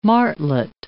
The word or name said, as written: martlet